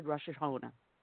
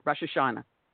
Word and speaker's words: Rosh Hashanah: And, oh, by the way, have a blessed Rosh Hashanah. I mispronounced that. Rosh Hashanah.